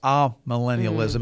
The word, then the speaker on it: amillennialism: Oh, is it ah? – I don't know. It just depends. I think I've heard it more often pronounced amillennialism. Mm. It's just the letter A in front of the word millennialism.